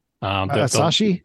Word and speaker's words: Asahi: Richard Stevens continues that Hector Martin, who does Asahi Linux, how do you pronounce that? – Asahi, and it's I think it's actually, it's Hector Marcan, I believe.